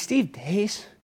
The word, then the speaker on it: Steve Deace: It's Steve Dace, but I want to say Dace.